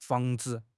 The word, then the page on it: 防治: HSK6